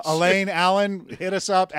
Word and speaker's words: Alain: I believe it's pronounced Alain. – I think you're right. But you never know if it's just a typo and should be Alan.